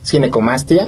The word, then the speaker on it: ginecomastia: Ginecomastia. – Exacto, ginecomastia.